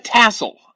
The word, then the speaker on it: TSOL: Tassel. – Tassel. – Yeah, it's spelled T-S-O-L.